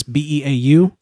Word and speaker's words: Beau: So at the real Beau York, my name is spelled kind of weird. It's BEAU.